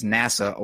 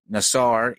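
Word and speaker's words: Nassar (נשא): The atheist 61 the Athens 619 the Hebrew word that is pronounced as NASA or more correctly Nassar is actually a slightly different variation.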